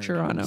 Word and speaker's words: Toronto: Toronto, you're not supposed to pronounce a second T. That's what I heard. – Toronto.